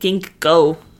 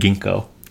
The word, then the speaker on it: Ginkgo: That also looks like Ginkgo. – Yeah, but it's pronounced Ginkgo.